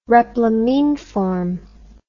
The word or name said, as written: replamineform